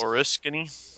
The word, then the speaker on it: Oriskany: and they're seeing no signs of oil near the Orinsky wreck. And I'm probably saying that wrong. I looked it up several times. The USS Oriskany. – Oriskany. Yeah, so you can join me in mispronouncing it.